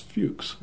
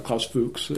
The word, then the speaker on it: Fuchs: Probably the most notorious was Klaus Fuchs. Klaus Fuchs, I believe, is the German pronunciation. Most of us, we pronounce it Fuchs over here.